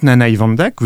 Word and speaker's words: Nanaï Vandak: Car le marchand qui écrit cette lettre, Nanaï Vandak, vous avez bien prononcé,